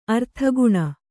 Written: ಅರ್ಥಿಗಣ

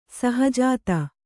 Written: ಷಹಜಾದ

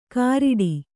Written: ಕಾರಿಡಿ

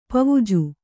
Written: ಪವುಜು

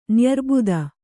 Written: ನ್ಯಾರೆಬತ್ತ

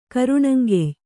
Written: ಕರುಣಂಗೆಯ್

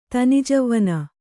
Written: ತಿಣ್ಣಜವ್ವನ

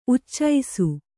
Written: ಉಚ್ಚಿಸು

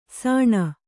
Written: ಸಣಿ

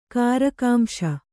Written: ಕಾರಕಾಂಶ

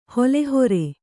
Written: ಹುಲ್ಲುಹೊರೆ

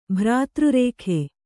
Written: ಭ್ರಾತೃರೇಖೆ

